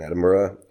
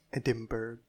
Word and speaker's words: Edinburgh: And we like took, for instance, we went to the Fringe Festival in Edinburgh with one of the plays I had written. – Edinburgh.